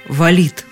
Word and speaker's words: валит: И так валит Такое ударение в слове «валить» появляется, когда что-то или кто-то двигается, падает или поднимается большой массой.